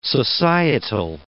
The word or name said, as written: societal